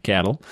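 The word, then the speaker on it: Cadel: Number 8, and I don't have any idea how to pronounce his first name, Cadell Evans. – Cattle.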